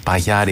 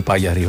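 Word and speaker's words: Pajari: Μάρτιν Σες και Σάμι Παγιαρή, Παγιάρη, εξαρτάται πώς διαβάζουν τα ονόματα των Φιλανδών οι άνθρωποι. Ναι. Εγώ που τα τονίζω πάντα στην προπαραλίγουσα, για μένα είναι Σάμι Παγιαρή ο άνθρωπος. – Έτσι, έτσι είναι, έτσι το λένε και οι, και οι Φιλανδοί.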